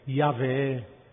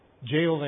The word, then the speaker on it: Yahweh: Pensate, il paradosso, una religione che segna 4 lettere, Yod, He, Wow, He, in ebraico, che poi sono state dette Yahweh, Jehovah è sbagliato sicuramente, ma Yahweh si ipotizza che la pronuncia, ma non è sicuro perché non sappiamo.